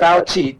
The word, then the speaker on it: Fauci: Fauci. Chi, like cheese. The moon's made of cheese. Everyone knows that. – Fauci.